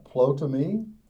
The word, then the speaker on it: Ptolemy: Uh, he as, and I may not always say these names correctly or they're said different ways, but I'm going to say it the way I pronounce it. Uh, Ptolemy, P T O L E M Y and he secured basically Europe and the land of Israel.